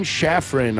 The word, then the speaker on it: Schaffran: We'd like to welcome today to Pit Pass Moto, Brian Schaffran.